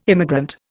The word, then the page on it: immigrant: Indian